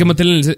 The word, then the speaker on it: Mattel: Es que Mattel en el CES... ¿Ves cómo lo dije bien? – En el CES.